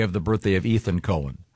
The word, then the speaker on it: Cohen: No, not when it's spelled C-O-H-E-N. – It's definitely Cohen.